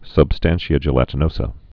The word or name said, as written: substantia gelatinosa